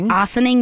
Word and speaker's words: Ossining: Ossining, New York.